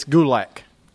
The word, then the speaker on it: Gulak: It's Gulak, not Gulak. – You said Gulak last week. – No, I didn't. – Yes, you did. – It's Gulak. Say it right.